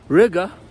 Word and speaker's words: Riga: I told you when I was in Riga, which rhymes with nigga,